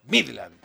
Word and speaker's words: Midland: Lo pronuncia bien Seba, porque la D no está al comienzo de la sílaba y por ende no se estresa. Y me encanta que diga estresa para referirse a una letra. Se dice Midland y se terminó.